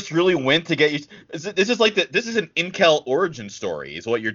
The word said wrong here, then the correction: incel: Time out. It's pronounced incel.